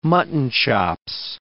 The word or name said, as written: muttonchops